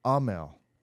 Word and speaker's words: Aamil: And I feel like it's Amel. – Yeah. – There's more emphasis on the A.